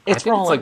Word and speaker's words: Rowling: It's Rowling. – It's like Rowling like bowling.